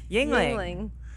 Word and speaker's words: Yuengling: Yingling. – A Yingling. – Yingling. – What's Yingling? – Yingling is a local beer. Yingling. And it's spelled Y-U-E-N-G-L-I-N-G.